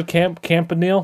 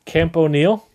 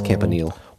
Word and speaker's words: Campanile: Camp O'Neill? – Yeah. – Like Camp, like someone named O'Neill started. – But it's spelled camp. – Nile. Camp O'Neill, but it's Campanile.